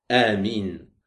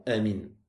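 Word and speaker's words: Amin (آمين): il disait Amin à haute voix et en allongeant les voyelles. En allongeant les voyelles, c'est-à-dire qu'il prononçait bien Amin. Et ne pas prononcer ce mot rapidement comme certains le font, Amin. Tu prends le temps de le prononcer en allongeant bien les voyelles et tu le fais à haute voix lors des prières à voix haute.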